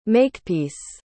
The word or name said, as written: makepeace